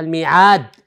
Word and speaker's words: الميعاد: نحن ننطقها الميعاد ولكنها محذوفة الألف إذا رجعت إلى القرآن الكريم ستجد أنها الميعد والألف هو ألف خنجري وليس ألف مد مثبت ليس